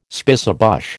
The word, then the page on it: spicebush: American English